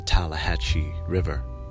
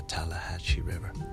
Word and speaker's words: Tallahatchie: And they finally got the call that they found Emmett's body in the Tallahatchie River. The Tallahatchie River. I think I said it right the first time. The Tallahatchie River.